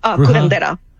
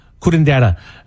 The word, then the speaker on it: curandera: When you say bruja, how do you say the second word? – Curandera. – Curandera.